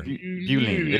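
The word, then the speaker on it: bowline: Buline. It's spelled like bow, like the first part of beautiful. – B-A-U. – Yeah, dash line. – L-I-N-E. Right? – Buline.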